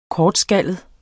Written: kortskallet

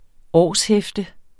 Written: årshæfte

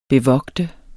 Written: bevogte

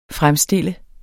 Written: fremstille

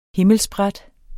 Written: himmelspræt

